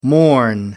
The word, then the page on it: morn: American English